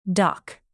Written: dock

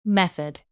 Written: method

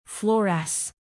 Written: fluoresce

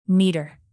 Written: metre

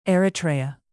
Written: eritrea

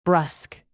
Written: brusque

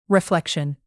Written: reflection

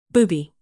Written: booby

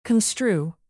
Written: construe

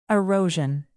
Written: erosion

